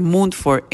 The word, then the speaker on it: Munford: Y es la 5570 Moonford, lo estoy pronunciando en español porque es Moonford, pero es Moonford, M-U-N-F-O-R-D, Road, R-D, Rally, R-A-L-E-I-G-H, coma N-C 27612. Unity Español 5570 Munford, M-U-N-F-O-R-D, Road, Rally, NC 27612.